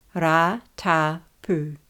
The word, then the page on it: Ratapu: Ra–ta–pu